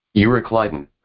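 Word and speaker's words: Euroclydon: But not long after, there arose against it a great temperous wind called the Lycidon. Let me see how to pronounce this. – Euryclidon. – Euryclidon. Called Euryclidon. And when the ship was caught and could not bear up into the wind, we let her drive.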